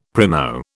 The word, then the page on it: primo: British English